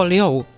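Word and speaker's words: liou (六): Vyslovuje se jako liou ve čtvrtém tónu a to se podobá slovu liou v prvním tónu, což znamená přitékat.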